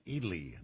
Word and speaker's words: Ely: It's pronounced, they tell me it's pronounced Ely, Nevada. Ely, Nevada.